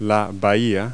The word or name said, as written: bay